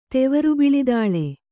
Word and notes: ತೆವರುಬಿಳಿದಾಳೆ: Kannada

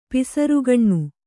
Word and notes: ಪಿಸರುಗಣ್ಣು: pisarugaṇṇu

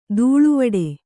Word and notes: ದೂಳುವಡೆ: Kannada

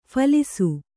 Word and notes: ಫಲಾಸಿ: phalāsi

